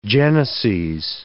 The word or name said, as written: geneses